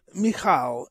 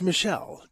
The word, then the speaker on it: Michal: More than once, I've heard you mispronounce the name Michal as Michelle. David's wife was not Michelle.